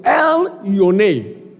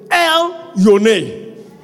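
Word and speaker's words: El-Yone: And then they're saying that when you say it, you should say L hyphen Y O N E. So L Yone. I can say it that way. Hallelujah. According to what I my reference, my reference was telling me how to say it. And it says E L hyphen Y O N E. So we are going to say, everybody say L Yone.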